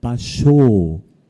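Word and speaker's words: Bashō: Oder ein langes Vokal wie Basho. Dieses O zählt für das japanische Ohr doppelt.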